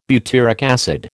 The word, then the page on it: butyric acid: American English